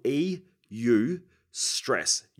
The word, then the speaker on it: eustress: Now, it's spelled E-U. Stress, eustress.